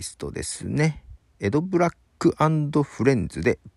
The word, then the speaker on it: EDBL: えー、エド・ブラック、綴りとしてはね、 EDBL と書くんですけども、 EDBL と書いてエド・ブラックですね。